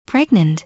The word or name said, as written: pregnant